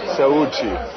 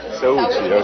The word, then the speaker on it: Saúde: Sauchi. – Sauchi, okay, Sauchi. Yeah, power? – Health, okay. Saúde.